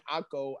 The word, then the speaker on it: Acho: Achu? Nah. – Emmanuel Achu? – I don't know how to pronounce his last name. Acho. Acho. – Emmanuel Acho. Acho. Where he has A-C-H-O.